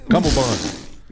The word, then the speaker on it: cummerbund: I thought it was cummerbund, but it's cummerbund. – Oh, there's no B? – Uh-uh. – So when I say it wrong on purpose, it's actually saying it right? Cummerbund.